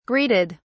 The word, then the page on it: greeted: British English